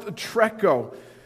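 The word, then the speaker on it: treko (τρέχω): Now, the Greek word for run looks like this. Yeah, right on top. It's pronounced treko.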